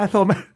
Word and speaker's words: Athol: which is, it is spelled A-T-H-O-L. That's right. Athol, Massachusetts. – Massachusetts? – Athol, actually, I'm from Athol, Massachusetts.